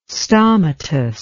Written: stomatous

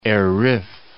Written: Er Rif

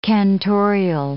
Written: cantorial

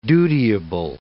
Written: dutiable

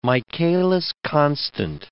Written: Michaelis constant

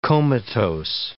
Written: comatose

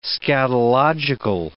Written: scatological

